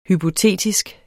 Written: hypotetisk

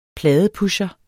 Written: pladeforhandler

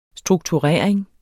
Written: strukturering

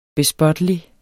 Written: bespottelig

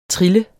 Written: trille

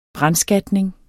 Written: brandskatning

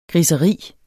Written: griseri

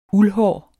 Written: uldhår

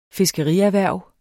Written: fiskerierhverv

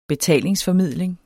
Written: BETALINGSFORMIDLING

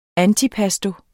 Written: ANTIPASTI

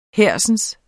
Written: hersens

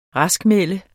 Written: raskmelde

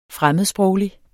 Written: fremmedsproglig

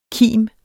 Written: kimets

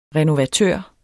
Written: renovatør

